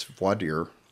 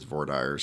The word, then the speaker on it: voir dire: I believe that's the Texas pronunciation. – Yeah, no, the Texas pronunciation is voir dire. And I think that pronunciation may have spread to some neighboring states, but in Texas it's voir dire.